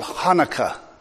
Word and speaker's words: Hanukkah: In the Hebrew alphabet, the H is a guttural sound. It is a H. So when they spell it with the CH, they're trying to get as close as they can to the original pronunciation. So to make it easy on my throat, I'm just going to say Hanukkah and not the H.